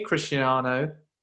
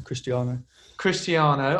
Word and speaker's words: Cristiano: But no, I did meet Cristiano, if I'm saying his name right. Chris, I can't even say it. – First time was fine, yeah. Cristiano.